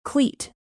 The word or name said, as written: cleat